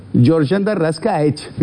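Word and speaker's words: Giorgian De Arrascaeta: Más fútbol internacional y pasamos a Flamengo, Independiente del Valle, con Georgian Darrascaech.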